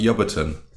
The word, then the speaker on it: Ebrington: You are, however, you wouldn't be able to tell that by reading it because it's a town called Ebrington. – Ebrington? – Ebrington, Ebrington, in Gloucestershire. Locally, it's pronounced Yubberton.